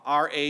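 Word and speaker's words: ra (רע): The term in the Hebrew is simply ra, R-A. You could pronounce it R-A-H, but it's just ra.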